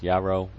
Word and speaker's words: Yaro: Or if you can't spell that, just do a Google search for my name, which is Yaro Y-A-R-O, and it will be the first result.